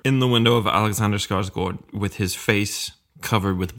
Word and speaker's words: Skarsgård: I'm going to pronounce it the Swedish way. – Go ahead. – Alexander Skarsgård.